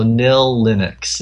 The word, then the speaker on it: Knillinux: Oh, yeah, it's KNILLINUX. So Nil Linux is the way to pronounce it.